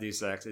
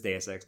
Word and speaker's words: Deus Ex: I'm like, oh, yeah, it's not Deus Ex. It is Deus Ex, because it's Deus Ex Machina, and that's the actual phrase, except it doesn't have the last word on the end.